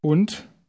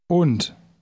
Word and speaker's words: Und: Und? – Notice in word 1 that the D at the end is pronounced like T. Say word 1 again. – Und.